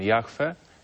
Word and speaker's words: Jahwe: Widzimy tetragram u góry, po lewej stronie, JHWH, które czytamy jako w Piśmie Świętym Jahwe, tak się właściwie je wymawia, i pod spodem Haya, czyli czasownik być.